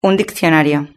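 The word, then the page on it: un diccionario: European Spanish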